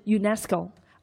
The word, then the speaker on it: UNESCO: 比如说美国的航空航天局，呃，我们在读的时候呢，我们会把它读成叫 NASA。还有联合国教科文组织，我们也会把它合在一起读 UNESCO。而不会把它分开哈，按照六个字母来进行朗读。